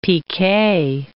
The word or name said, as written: PK